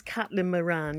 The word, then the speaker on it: Caitlin Moran: Her name is spelled M-O-R-A-N. As far as I'm concerned, that's pronounced Moran, isn't it? Or Moran or something. What did you say it was? – I think she's Caitlin Moran, yes. – Caitlin Moran, okay, yeah, okay.